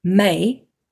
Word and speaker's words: May: But if you looked at like my middle name, which is May, there's only one syllable.